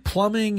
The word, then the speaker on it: Plumbing: Will you please pronounce their name and everything correctly? – Tarpy Plumbing, Heating and Air.